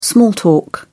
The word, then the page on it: small talk: British English